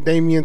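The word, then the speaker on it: Damian: I just wanted to say my name again, Damien Tucker.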